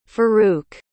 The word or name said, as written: faruq